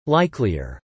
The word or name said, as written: likelier